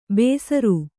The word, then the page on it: ಬೇಸರು: Kannada